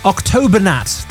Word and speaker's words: Octobernat: But this is Octobernat. I feel like I just broke that name. Octobernat. That's it. I've just remembered how to pronounce it.